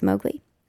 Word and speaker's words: MWGLI: In the Milwaukee music scene, she's better known as MWGLI, which I believe is pronounced Mowgli. Born in Bogota,